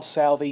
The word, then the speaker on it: Southey: Bob Southey, he actually probably pronounced it Southey. There's some debate about this, and there's still some debate about what the right way to pronounce it is. But he rhymes with mouthy at one point. So maybe we should say Southey.